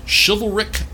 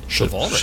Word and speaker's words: chivalric: And so a space opera is a subgenre of science fiction that emphasizes space warfare, melodramatic adventure, interplanetary battles, as well as chivalric... Is that how you pronounce that word? – Chivalric. – Chivalric. – Chivalric, yes.